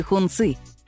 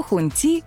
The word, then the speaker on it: Хунци: Еще один электрический седан премиум-класса из Китая от премиальной марки «Хунци». К слову, сами китайцы произносят это название немного иначе. Не то хунти, не то хунчи.